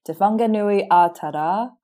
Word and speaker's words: Te Whanganui-a-Tara: Did I pronounce Te Whanganui, Ātara? Yeah, Ātara. You did pretty well.